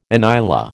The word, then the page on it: inyala: American English